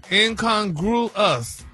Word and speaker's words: incongruous: No, he had the last syllable wrong. He just, now you got the right syllables. And it's broken down for you. – Yeah, break it down. – Incongruous.